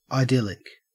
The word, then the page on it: idyllic: Australian English